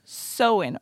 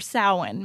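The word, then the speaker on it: Samhain: Right here it says pronounce Sowin or Sowin, maybe. – Huh. – Yeah, right? – It doesn't have half the letters in it that it says that's how you pronounce it. Is it a word from a different language? – It's Celtic. – Celtic. – Yes. – That's like Irish. – Yes. – Or it's Irish, right?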